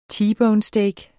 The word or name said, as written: T-bone-steak